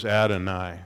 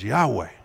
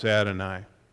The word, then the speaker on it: Adonai: And he looked at it, and he said, that word is Adonai. And I said, I thought it was Yahweh. Thought that's how you'd pronounce it. No, it's Adonai.